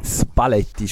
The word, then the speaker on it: Spalletti: Spalletti spricht man korrekterweise aus.